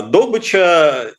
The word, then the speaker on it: добыча: Да, я, кстати, обнаружил, что добыча – это допустимое ударение, у Даля оно именно так стоит, уже у Даля оно стоит как э, добыча, и, э, в общем, у Крылова, если вспомним, Ягненка видит он на добычу стремится, угу.